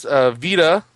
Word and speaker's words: Vita: And then the one for the PS Vita, I said it right. – Yeah, finally. – I normally say Vista for some reason.